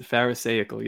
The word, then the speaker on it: Pharisaical: Pharisaical. I bet you that's how it's pronounced, but go ahead. – Yeah, and that is Pharisaical, yes.